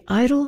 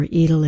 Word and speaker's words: Idyll: It is the Idyll for Strings, or Idyll if you prefer that pronunciation.